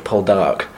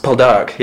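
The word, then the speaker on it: Poldark: Paul Dark, yeah. If you're in the Cornish, you stress the second syllable.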